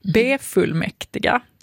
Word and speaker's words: befullmäktiga: Och att då det här med B-prefixet kan få betoning. Ett exempel då som de har, och nu ska vi se om jag kan säga det här. B-fullmäktiga. Alltså som vi vill då säga befullmäktiga.